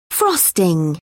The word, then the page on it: frosting: British English